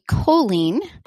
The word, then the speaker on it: Coleen: I'll tell you, my name is actually Colleen.